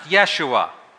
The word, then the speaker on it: Yeshua: It's also pronounced Yeshua, reflecting the Hebrew pronunciation.